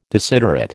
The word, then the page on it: desiderate: American English